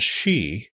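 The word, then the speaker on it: sí: The first one is ban, B-E-A-N, but pronounced ban, which means woman. It can also mean wife. And she, S-I with an accent on the I, which means fairy.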